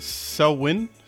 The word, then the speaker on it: Samhain: It's pronounced Selwyn. Selwyn. – How the fuck is that pronounced, Selwyn? – Yeah, it looks, for the folks at home, it looks like Samhain, mm-hmm. but it's pronounced Selwyn or Selwyn.